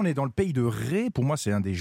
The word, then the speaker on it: Retz: Alors ça s'écrit Retz, mais ça se prononce Ré.